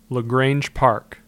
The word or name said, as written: LaGrange Park